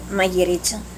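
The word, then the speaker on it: magiritsa: We call it Magyritsa. – Magyritsa. How do you spell that? – M-A-G-I-R-I-C-H-A. – Magyritsa.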